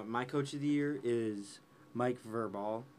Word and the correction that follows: Vrabel: Or Verbal. – Vrabel.